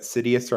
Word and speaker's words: Sidious: The way I remember it, which is problematic because I don't like to call people ugly or not, but Sidious rhymes with hideous, and after his fall and the Empire and stuff, like, hideous.